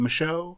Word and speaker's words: Micheaux: Am I butchering his last name? Is it Micheaux? – That's correct.